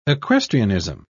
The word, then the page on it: equestrianism: British English